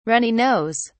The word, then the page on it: runny nose: British English